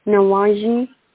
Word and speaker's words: nawanji: So how do you say the Z? Nawaji? – Nawanji, like a almost like an SH or a ZH sound. – Nawanji.